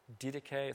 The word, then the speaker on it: Didache: Do you know the work called the Didache? Have you ever heard that term? Didache, it's spelled D-I-D-A-C-H-E.